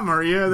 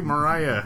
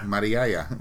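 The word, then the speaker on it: Mariya: That's not Maria. That's like Mariah. – Mariaya.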